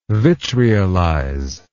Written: vitriolize

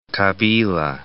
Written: Kabila

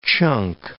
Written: chunk

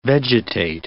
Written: vegetate

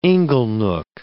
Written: inglenook

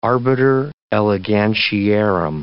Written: arbiter elegantiarum

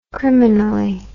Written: criminally

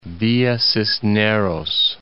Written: Villa Cisneros

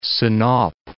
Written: Sinop

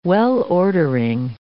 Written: well-ordering

